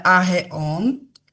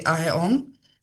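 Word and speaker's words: Aheon (αἰών): Svet je tiež napríklad Aion, áno, teda Aheon, hej, tak sa to vlastne vyslovuje Aheon, čo znamená vek, obdobie času.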